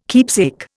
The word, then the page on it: keepsake: Indian